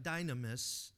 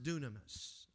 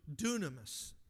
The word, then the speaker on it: dunamis: The word for power is the word, it's pronounced, I know it looks like dynamis, but it's pronounced dunamis. Y's are pronounced with like a U in Greek. So dunamis, dunamis power.